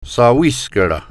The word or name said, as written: Thawiskaron